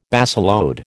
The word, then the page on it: baseload: American English